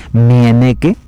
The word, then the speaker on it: Mieneke: la gran Meineke van der Velden, Mieneke, así se escribe su nombre, Mieneke, Van der Belden.